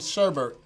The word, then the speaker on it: sherbet: But there's no R cool. in sherbet.